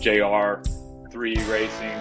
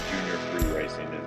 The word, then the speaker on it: Junior 3 Racing: It often gets pronounced JR 3 Racing or other ways, but it's really Junior 3 Racing is the right way to say it.